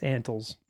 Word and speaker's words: Antles: It's Antles. Antles. Scott, please say it right.